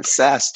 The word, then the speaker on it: Sass: Sass, just like it sounds. Very, very sassy.